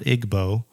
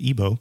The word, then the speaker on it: Igbo: As you think about, which I just learned, you don't pronounce the G. It's not Igbo. So I apologize for mispronouncing it, but it's Igbo.